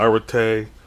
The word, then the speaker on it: arete: But the word is arete, and I'll spell it. A-R-E-T-E. Arete.